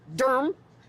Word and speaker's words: Durham: We are in Durham, D-U-R-M as they pronounce it here.